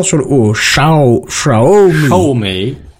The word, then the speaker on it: Xiaomi: Juste pour, pour préciser, il y a Randall Flack qui nous dit dans les commentaires que ça se prononce Xiaomi, pardon. – Xiaomi, c'est Xiaomi Ouais.